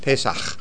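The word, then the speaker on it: Pesach (פֶּסַח): So Pesach is right up on top, and it's got an English name and the Hebrew name, and it's P-E-S-A-K-H, I would spell it. It's got the ch sound on the end. Pesach. Pesach.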